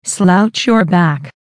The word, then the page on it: slouch your back: American English